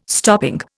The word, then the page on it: stopping: Indian